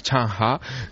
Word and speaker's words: Zheng He: Allí no se llama Zheng He, allí se llama Chang Ha, que es...